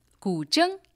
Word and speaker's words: guzheng: Wu Fei is here with her Chinese zither 21-string zither, which is pronounced how? – Gu Zheng. – Gu Zheng. I'm working on the pronunciation. It looks like Gu Zheng. – Yeah. – But it's pronounced Gu Zheng.